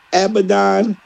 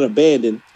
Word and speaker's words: Abaddon: Yes, Abaddon, who I've been calling abandoned, was on Rampage.